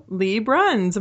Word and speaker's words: Bruns: You got a little taste there, but longtime Alison fan, new to our show, Lee Bruns.